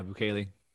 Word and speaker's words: Bukele: I always butcher his name, Bukele. Correct me if I'm wrong. – Yep. – Yeah, Bukele.